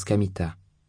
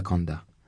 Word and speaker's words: Kamita: Mon nom s'écrit avec le caractère Kami, Dieu, et Ta, rizière, et se prononce Kamita, et pas Kanda.